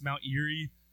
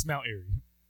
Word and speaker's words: Mount Airy: It's not pronounced Mount Erie or Moberly, so it's Mount Airy.